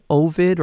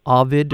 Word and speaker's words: Ovid: It is out now on release on Ovid or Ovid, depending on how you choose to pronounce it, OVID.TV.